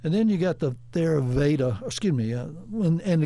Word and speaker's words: Theravada: Yeah, Theravada.